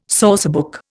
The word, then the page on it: sourcebook: Indian